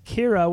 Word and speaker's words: Qi'ra: Amelia Clark as Kira, which is spelled Qi'ra.